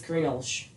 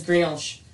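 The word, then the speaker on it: Greenhalgh: And it's spelled Greenalgh, but I think it's Greenalge. – Again, the pronunciations like Welsh words and Irish words and Scottish words are weird. So. – Yeah, so it's Greenalge.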